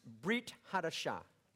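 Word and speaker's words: Brit Hadashah (ברית חדשה): Now, in Hebrew, the words New Testament are pronounced Brit Hadashah.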